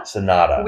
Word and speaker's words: Sanada: Sanada.